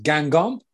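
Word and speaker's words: Guingamp: Gwyn Camp, if that's how you say it, with their Umbro set shirts. – I think it's pronounced Gangon, and it's one of my favourites of the year so far.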